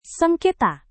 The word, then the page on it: sengketa: indonesian